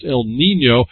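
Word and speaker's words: El Niño: Oh, this one's got an N-Y. It's actually pronounced El Nino,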